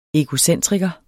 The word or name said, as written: egocentriker